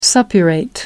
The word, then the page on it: suppurate: American English